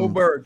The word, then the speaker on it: Åberg: Åberg.